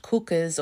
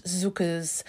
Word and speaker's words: Cukurs: Now, yes, if you're looking at the description or the title of this show, which luckily you did, it looks like his name is pronounced Cukurs or Cukurs, his surname, but it's actually pronounced Cukurs, just to clarify that.